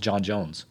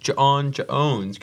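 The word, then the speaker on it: J'onn J'onzz: Well, that's how it's spelled with the apostrophes in there and the Z's, but I thought the clever bit of it was always it's pronounced John Jones. – I remember being like 4 and my dad being like, it's pronounced John Jones because there's a apostrophe.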